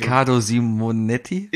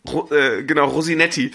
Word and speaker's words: Riccardo Simonetti: Du musst den Namen schon richtig aussprechen. – Riccardo Simonetti? – Ja, sehr gut. – Heißt das so? – Ja. – Okay. – Wie hat er ihn genannt? Rosinetti.